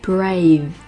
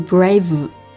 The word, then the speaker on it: brave: How to be brave， how to be brave， brave 字母 V 的读音一定要注意一下， v 上排牙齿碰到下唇，动作就结束了，不要读 brave。